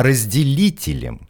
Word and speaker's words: разделителем: Разделятелем. – Не разделятелем, а разделителем.